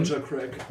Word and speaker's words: Elijah Craig: Elijah Craig.